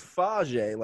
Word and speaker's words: Fage: Mark Fags. I don't know if I'm pronouncing the last name right. – I think it's Fage, like yogurt.